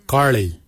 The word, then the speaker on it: Giilyi: Darb is spelled D-H-A-A-B. It is a traditional knife, a darb. For boomerang we say garli. Garli is the traditional name for boomerang. Garli is spelled G-I-I-L-Y-I, garli.